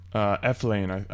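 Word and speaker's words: Elflein: You know, he's another first-round NFL surefire guy, and, you know, add in Pat Elflin from Ohio State, the guard. – Eflane, I believe it's pronounced.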